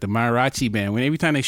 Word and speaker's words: mariachi: Like mariachi, am I saying it right? – Yeah, that's it. Mariachi.